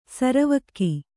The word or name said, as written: ಸರವಕ್ಕಿ